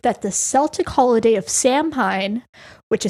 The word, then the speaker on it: Samhain: It looks phonetically weird, but I'm pretty sure it's Samhain. – Okay, well, it is Samhain. It is spelled Samhein.